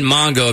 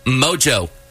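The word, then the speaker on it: Mongeau: We've been saying Mongo because of the way it's spelled. We just figured it's kind of French. There's an N in there and a G and the U-E-L-U. It's Mojo. – Mojo? – Yeah. – Mojo. – Sarah says, as much as I know this, Tana Mongo is pronounced Mojo.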